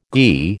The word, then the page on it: ghi: American English